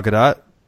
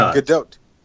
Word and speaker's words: Gadot: It's Gal Gadot? – Yeah, Gal Gadot. – Or is it Gadot? How is the O? Is it a long O or a short O? – It's the O as in body.